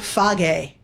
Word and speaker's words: Fage: You got to try the Phage. That's how you say it in Greek. Phage. F-A-G-E. – Is that F-A-G-E? – Yes.